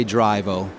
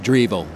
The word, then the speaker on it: Drivo: Americans will say Drivo. Being Italian, I pronounce as E, so it's Drivo.